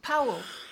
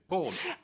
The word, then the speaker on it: Powell: Oh, yes, Powell. – But don't pronounce it like that, because he never pronounced it like that. He pronounced it in the original Welsh way. Paul.